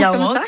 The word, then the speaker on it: Yaouank: C'est ça, se prononce Yaouank, comme ça.